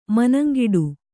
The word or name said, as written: ಮನಂಗಿಡು